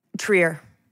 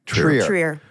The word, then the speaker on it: Trier: Is it Trier or Trier. – Trier.